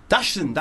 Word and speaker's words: Dachshund: Is it Dachshund? – Dachshund, that's it.